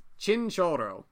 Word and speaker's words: Chinchorro: In the Atacama Desert, where conditions are perfect for mummifying, there was several thousand years BCE, there was the Chinchorro people. – The Choro people. – Chinchorro. I can't roll my R's, but normally my Spanish pronunciation is a lot better than that.